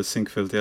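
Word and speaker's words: Sinquefield: Uh, torej, govora je o, uh, če vam pravi, izgovoru uh, Sinkfield kapu. – Mislim, da je Sinkfeld, ja. imenuje se po Rexu Sinkfeldu, to je nek ameriški milijarder.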